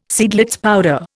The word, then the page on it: Seidlitz powder: Indian